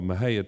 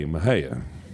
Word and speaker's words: Mexia: She's from a little town called Mahia, Texas. It's spelled just as it sounds, M-E-X-I-A, Mahia.